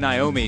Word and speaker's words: Noemi: She's named Naomi. She goes by Emmy. It's spelled N-O-E-M-I, and they call her Emmy.